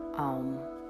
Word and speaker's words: Aum: Now gently introduce the mantra Aum. This sacred syllable is not just one sound, it's actually three. A, O, M, indicating the continuity of past, present, and future.